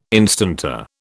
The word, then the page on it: instanter: British English